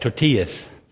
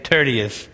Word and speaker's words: Tertius: I, Tertius, or would you pronounce that tortillas? This reminds me of a Mexican dish or something. But we'll say Tertius this morning.